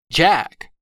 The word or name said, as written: jack